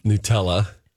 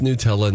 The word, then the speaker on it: Nutella: I think you're supposed to pronounce Nutella Nutella. – That's the way I say it. – You say Nutella. – Yeah, because it says nut. – Yeah. – Ella. – Right. Ella. Ella. – Ella. Nutella.